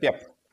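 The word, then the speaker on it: Пепе: Я говорю про Варелу, Гонсалеса, этого ПП, который, как у него там ударение на второе Е, да, или на первое, чтобы не путать Пеп. – С... – Португалии говорят Пеп.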